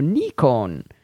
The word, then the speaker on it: Nikon: Regarding the Nikon pronunciation homework assignment, in Japanese, it would sound something like Nikon. If you're, or let's see, he says slash N-I-K-O-N slash if you prefer. So Nikon. He says, of course, since I'm an American, I pronounce it the same way you do when I'm talking English.